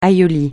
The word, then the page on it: aïoli: francais